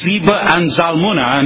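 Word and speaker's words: Zebah and Zalmunna: And he said to Zeba and Zalmunna, and I understand last week I was showing my dyslexia and pronouncing it incorrectly.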